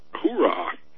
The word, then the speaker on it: Huayra: It's a Pagani H-U-A-Y-R-A. Huayra? – Hoorah, yeah.